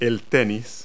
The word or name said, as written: tennis